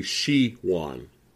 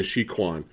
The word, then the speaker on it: Shiwan: Maybe the you know uh, I keep saying his name. I was saying his name wrong. Believe it or not, it's actually Shiwan. I was saying Shi Kwan.